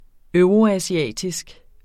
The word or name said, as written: euroasiatisk